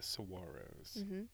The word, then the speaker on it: saguaro: So, yeah, you maintain your regular yoga practice in the morning, going amongst the saguaros, which we can finally now pronounce right, I think. Saguaro.